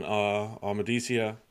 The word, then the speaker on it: Amadicia: So they keep moving, and eventually they find themselves in Amadicia. Sue me, because I probably said it wrong, but whatever. – No, you said it correctly.